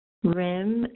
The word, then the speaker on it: Rim: But just in case, Rim is R-I-M, like Kim, but instead of a K, it's an R.